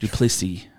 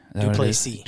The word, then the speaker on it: du Plessis: It, the S is silent, isn't it? That the S said? is silent. du Plessis. Duplessis.